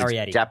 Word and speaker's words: Arrietty: And number 11 is The Secret World of Arrietty, if I'm saying that properly. – Yes, it's Arrietty.